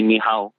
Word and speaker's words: ni hao: So, the basic greeting would be ni hao, you know? And then, you know, just say it one more time again so we can. – Ni hao.